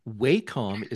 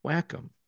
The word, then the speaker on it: Wacom: Wacom is how I said it for years Yeah. – until talking with Wacom employees who pronounced it Wacom.